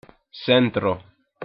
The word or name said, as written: centro